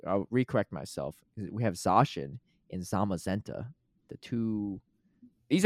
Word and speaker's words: Zacian: August 18th to August 31st, we got Zacian, or I think it's pronounced Zacian, actually, because that's how Gabby Snyder pronounces it, and I assume Gabby Snyder knows more than me.